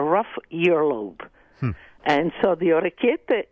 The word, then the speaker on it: Orecchiette: Orecchiette with clams Orecchiette. – And zucchini. Orecchiette. – Orecchiette.